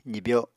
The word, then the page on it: zibyo: apatani